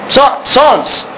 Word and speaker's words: Psalms: Psalms. It's written with a P, but the P is silent.